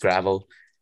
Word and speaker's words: Gravelle: I usually quite a lot that I've got off the cuff, but our good friend's Gravel is one that's spelled with E-double-L-E at the end, but it's still pronounced Gravel.